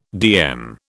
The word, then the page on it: DM: British English